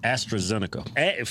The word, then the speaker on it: AstraZeneca: AstraZeneca.